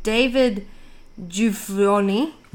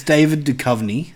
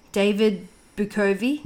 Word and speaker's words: Duchovny: David Bukovy. – It's not even a B. It's a D. David Duchovny.